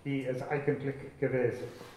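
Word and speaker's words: wie es eigentlich gewesen: E as eigentlich gewesen. And I hope I've got my, my German pronunciation right there.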